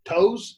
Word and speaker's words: Towes: T-O-W-E-S. How do you say that? – T-O-W-E-S. Pose? – Toes?